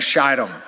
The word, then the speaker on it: Shittim: You want to make sure and get the long I sound on Shittim. If you don't, it completely changes the context of what's going on here.